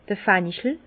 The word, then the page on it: d'r Fenchel: Alsatian; Bas-Rhin; Herrlisheim